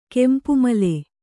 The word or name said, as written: ಕೆಂಪುಮಲೆ